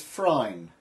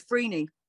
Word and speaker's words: Phryne: Is she Phryne or Phryne? – I think she's Phryne.